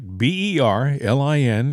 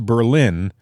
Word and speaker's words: Berlin: B E R L I N in Connecticut isn't pronounced Berlin. It's Berlin.